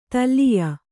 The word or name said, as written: ತಲ್ಲೀಯ